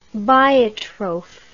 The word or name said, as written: biotroph